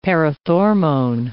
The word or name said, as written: parathormone